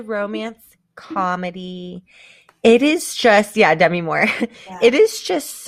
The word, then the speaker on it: Demi: Demi Moore? Is that – did I say that right? – It's Demi. – Why did that just sound wrong to me? – Demi? – No, that was right.